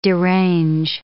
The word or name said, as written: derange